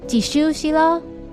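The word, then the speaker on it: 自首しろ: 补充一下，仇思郎的日语念作しゅうしろ，而去自首吧日语念作じしゅうしろ。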